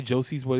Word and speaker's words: Josiesboy: I hate when people say it and they think I'm pronouncing it plurally, but it's really spelled that way. – Yeah. – So they don't say Josie's boy, they say Josie boy.